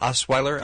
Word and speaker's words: Osweiler: Osweiler? – Yes, Osweiler.